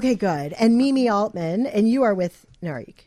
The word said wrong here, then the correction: Nari GC: It's Nari GC, actually. – Nari GC. – Yes.